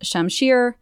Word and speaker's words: shamshir: So yes, a high-quality pair of cobalt knuckles, a cobalt shamshir, and I hope I'm pronouncing that correctly.